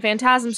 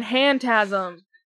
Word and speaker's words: Phantasm: And Phantasm starts with a P. It's a silent P. Well, no, it's not a silent P. It's PH, which makes a F. If it was a silent P, it would be Fear and Hantasm.